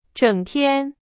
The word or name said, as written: 整天